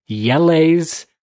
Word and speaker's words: Jelle: So what the MarbleLympics is it is a series of videos on a YouTube channel called, I believe it's Yelle's Marble Runs. Yelle is J-E-L-L-E apostrophe S. – Okay. – But I looked up the pronunciation, and that's as best as I could find what it is.